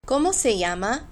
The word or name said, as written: cómo se llama